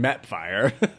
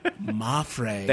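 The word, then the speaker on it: Mapfre: Behind Mapfire. – Mafre.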